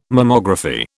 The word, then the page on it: Mammography: British English